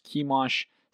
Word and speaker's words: Chemosh: Looks like Chemosh, but it's pronounced Chemosh. C-H-E-M-O-S-H.